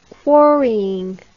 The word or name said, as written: quarrying